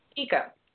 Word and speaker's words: Kiko: Like, you know, because, like, Kiku never, is that, am I saying her name right, Kiku? Kiko? – Mm-hmm. Kiko. – Kiko, yes.